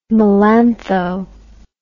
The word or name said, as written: melantho